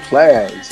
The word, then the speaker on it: Plies: No, Plies. P-L-I-E-S. – Yeah, oh, Plies. – Yes. Yeah, she said please. – No, I didn't say please. – No, she said Plies.